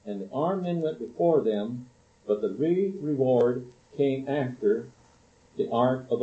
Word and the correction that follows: rearward: We should probably pronounce it rearward. And it is an English word. It means the rear guard.